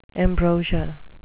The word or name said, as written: ambrosia